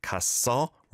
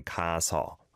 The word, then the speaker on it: 갔어: But you need to pronounce it 갔어 rather than 가서.